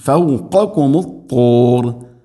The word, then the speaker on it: فوقكم الطور: ഐൻ ورفعنا, ورفعنا എന്നല്ല, ورفعنا فوقكم الطور, فوقكم, قافും كافും മഖ്റജ് അടുത്തടുത്തുള്ള അക്ഷരങ്ങളാണ്, قافും كافും. ഉച്ചാരണം പ്രത്യേകം ശ്രദ്ധിക്കണം. ഫൗഖകും, ഫൗഖകും ത്വൂർ, ത്വൂർ. പ്രത്യേകിച്ചും ത്വാന് ദൊമ്മൊക്കെ വരുന്ന സമയത്ത് കൃത്യമായിട്ട് ശ്രദ്ധിച്ച് ഉച്ചരിക്കണം, അല്ലെങ്കിൽ അത് സാധാരണ ടാഇനെ കൊണ്ട് പറയുന്ന രൂപത്തിൽ ഫൗഖകും ത്വൂർ എന്നാവും.